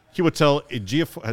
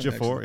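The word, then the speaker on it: Chiwetel Ejiofor: Kiwetel Ejiofor, is that how you say it? – Yes. – Yeah. – Well done. – Ejiofor, yeah.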